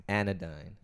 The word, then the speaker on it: anodyne: Anodyne. It's anodyne. – And that's how you pronounce it.